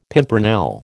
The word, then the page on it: pimpernel: American English